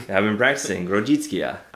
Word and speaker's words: Grodziskie: I did notice that there is, like, a preferred German word pronounced Grätzer. Grätzer. So that's a little bit easier to look and see. It's as opposed to Grodzicka.